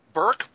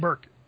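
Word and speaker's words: Berke: Burke Breathed. – Burke.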